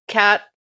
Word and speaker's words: quatre: Well, that's how the French say four. Quatre. It's quatre. I looked up the pronunciations on YouTube, and then I wrote them out phonetically. Quatre. – It sounds more Spanish. You know what? – Well, this cuatro would be Spanish, right?